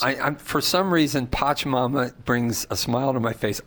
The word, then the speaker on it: Pachamama: Is it Pachimami or what's the Pachimami, Pac-Man? – Pachamama.